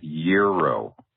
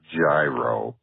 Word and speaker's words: gyro: And finally, it's Euro, like 365 degrees. Euro, not gyro, like a gyroscope. Euro.